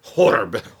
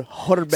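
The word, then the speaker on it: Ohrbe: It's O-H-R-B-E. – Yes. – Horb. – Horb. Horb. It's actually pronounced horb. – Or stupid. You guys are idiots. – Or horbe.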